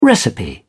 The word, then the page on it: recipe: British English